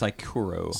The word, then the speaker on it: Saikoro: Okay, and that's Sykoro. – Sykoro, S-A-I-K, O-R-O, Sycoro.